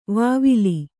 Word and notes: ವಾವಿಲಿ: vāvili

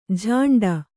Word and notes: ಝಾಂಡ: jhāṇḍa

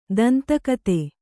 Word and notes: ದಂತಕಥೆ: danta kathe

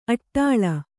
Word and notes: ಅಡಳು: aḍaḷu

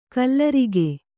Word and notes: ಕಲ್ಲರಿಗೆ: kallarige

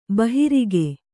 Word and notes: ಬಾಹುರಕ್ಕೆ: Kannada

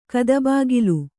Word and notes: ಕದಬಾಗಿಲು: kadabāgilu

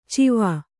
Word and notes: ಚಿವ: civa

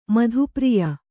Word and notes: ಮಧುಪ್ರಿಯ: madhu priya

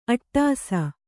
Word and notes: ಅಟ್ಟಸ: aṭṭasa

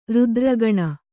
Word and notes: ರುದ್ರಗಣ: rudra gaṇa

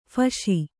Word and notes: ಫಾಸಿ: kannada

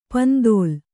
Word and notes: ಪಿಂತಿಲ್: pintil

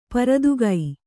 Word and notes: ಪರದುಗೈ: paradugai

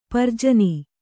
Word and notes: ಪ್ರಜನ: kannada

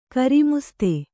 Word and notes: ಗ್ರಾಮಸ್ಥ: grāmastha

